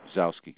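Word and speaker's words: Gzowski: Peter Gazowski doing interviews and shit like that, you know. – The G is not pronounced. – Zowski.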